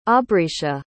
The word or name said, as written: aubrecia